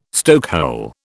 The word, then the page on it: stokehole: British English